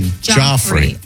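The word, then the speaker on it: Giuffre: Late Epstein victim Virginia Jeffrey, is that how you say her name? Am I saying that? Goffrey? Do you remember her? – Virginia Joffrey.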